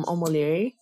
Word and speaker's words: Omolere: I am Omolere, that's spelled O-M-O-L-E-R-E, on the CHAOSS community. I'm Omolere, but O-M-O-L-X-R-E.